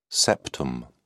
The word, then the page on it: septum: British English